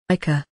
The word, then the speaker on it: ichor: spelled I-C-H-O-R, is pronounced... ichor. – Ichor. In British English, ichor. Ichor. – Okay, I may have overemphasized the or part of ichor. It should just be ichor.